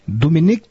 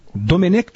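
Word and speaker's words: Domènec: Eu jogou, queria só que perguntar para ele me confirmar qual é a pronúncia correta em catalão do novo técnico do Flamengo. – Dominic Torrent. Dominic Torrent.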